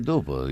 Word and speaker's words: dopo: Caro Pino, prego te e i tuoi ospiti di non pronunciare mai le parole dopo, posto, risposta, 14, con la O chiusa. È sbagliato e di cattivo gusto. I toscani pronunciano con la O chiusa. I toscani pronunciano in maniera scorretta. La lingua italiana è ufonia. Aiutatemi. Perché? E si dice dopo, risposta, posto.